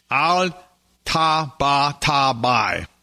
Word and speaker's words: Al-Tabatabai: Al-Tabatabai.